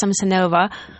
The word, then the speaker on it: Samsonova: Hi, my name is Tonia Samsonova. Samsonov, actually, I can pronounce my surname properly even in English.